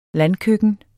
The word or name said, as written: landkøkken